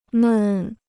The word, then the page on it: 闷: mèn